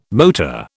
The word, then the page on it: motor: British English